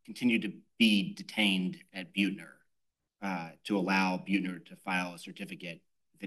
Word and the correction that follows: Buettner: I think it's pronounced Buttner.